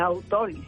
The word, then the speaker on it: autólisis: Sigan algunas modas, por decirlo de alguna manera, que puedan conducirlos a la autólisis, ¿no? Uh-huh. Autólisis, se pronuncia así.